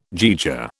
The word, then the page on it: jaeger: British English